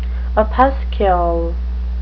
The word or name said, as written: opuscule